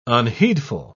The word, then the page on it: unheedful: British English